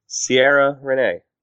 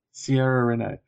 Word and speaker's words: Ciara Renee: If not, C-I-A-R-A. – You're asking Dave for pronunciation. – Yeah, no. How do you say it? C-I-A-R-A. – Hold on. Ciara Renee. – Ciara Renee. Yes.